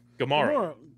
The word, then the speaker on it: Gumaro: Gumaro.